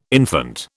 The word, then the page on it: infante: British English